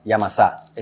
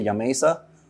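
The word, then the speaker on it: Yamasá: Yamasa in Spanish, yeah. – Yamasa? – So some people say Yamesa. No, that's not right. That's not it? Yamesa is not it.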